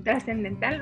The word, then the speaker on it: trascendental: Eh, todo esto ayuda a algo mucho más importante y mucho más transcendental. – ya lo dije mal. Transcendental. trascendental, no lo dije es, ayuda a la autorregulación la autorregulación, un proceso tan importante en todas las etapas de nuestra vida.